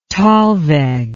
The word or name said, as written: thalweg